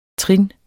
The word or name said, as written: TRINNETS